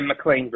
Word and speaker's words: McLean: Yeah, McLean, Virginia.